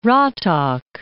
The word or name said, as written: Ratak